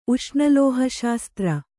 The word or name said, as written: ಉಷ್ಣಲೋಹಶಾಸ್ತ್ರ